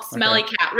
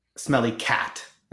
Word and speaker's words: Smelly Cat: And then we got to the taping, and Lisa was pronouncing it as Smelly Cat, and she was hitting cat.